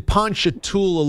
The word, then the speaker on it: Ponchatoula: Kevin's in Ponchatoula, Louisiana.